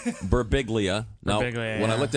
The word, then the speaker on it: Birbiglia: Joining us in the studio, comedian Mike Birbiglia. Mike, it's good to see you. – All right, one clap. – Is that the proper pronunciation, Mike? – Yeah, you can say whatever you want.